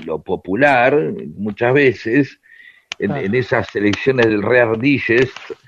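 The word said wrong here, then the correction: Reader's Digest: eh, como mierda se pronuncia, eh, que no me acuerdo cómo se dice. – Reader Digest. ¿Cómo? Reader Digest.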